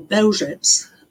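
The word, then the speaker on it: Bełżec: Well, Belzec, or Belzec, as it's pronounced in Polish, was a factory and death was its product.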